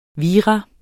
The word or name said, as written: virus